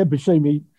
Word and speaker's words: Buscemi: Yeah, Buscemi.